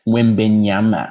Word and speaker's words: Wembanyama: But Wembanyama.